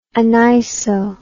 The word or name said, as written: aniso